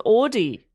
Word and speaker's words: Audie: This is Audie. So that's different. – How do you spell that? – A-U-D-I-E. – Oh, but like Audi the car, but... – Audie. – A bit different.